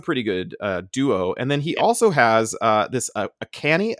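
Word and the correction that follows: Achane: Is that how you say it? Akani? – Devon A-chain. – Oh, A-chain.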